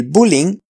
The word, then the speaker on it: bullying: 这个 bullying 啊、um, 是英语对吧？所以呃，我们西班牙人说 bullying，嗯，好像那个最后的那个 g 就不会发音，bullying，bullying，¿vale? A mi hijo le cambiamos de colegio porque le hacían bullying. El bullying es un problema muy serio. El bullying es un problema muy serio.